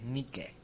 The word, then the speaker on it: Nike: Bueno, es que, realmente la pronunciación correcta de la diosa es Nike.